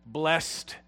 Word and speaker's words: blessed: And in English, we should pronounce this with stress on the second syllable, blessed, to differentiate it from the word bless, the verb. This is blessed.